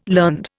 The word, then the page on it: learned: Indian